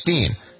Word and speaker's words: Palestine: You'd think Palestine, but I've been told it's Steen.